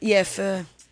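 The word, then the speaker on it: phở: And isn't it pronounced fur anyway? – It's actually, fur.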